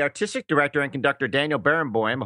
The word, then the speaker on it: Barenboim: Well, Daniel Barenboim, and yes, you did say his name correctly,